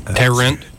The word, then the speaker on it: Tarrant: Last week, three men went to a convenience store here in Alabama in Tarrant. Did I pronounce that right? – Tarrant. – Tarrant. – Yeah.